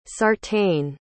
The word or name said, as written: sartain